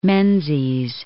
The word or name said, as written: Menzies